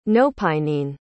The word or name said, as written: nopinene